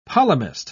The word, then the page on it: polemist: British English